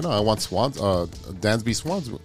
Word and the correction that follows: Dansby Swanson: Uh, why can't I say his name today? Uh, Dansby Swanson.